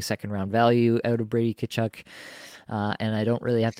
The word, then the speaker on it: Tkachuk: I think the next guy that I'm going to have to take here is Brady Tkachuk. I said it intentionally slowly so I could get that pronunciation right for everybody there. Brady Tkachuk, I just feel like he's so dependable here.